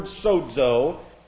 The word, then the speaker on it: sozo: This is actually the Greek word sozo, which is what you would expect to find for the term saved being translated. S-O-Z-O, sozo. This is S-O-Z-O, sozo. Both long O's there.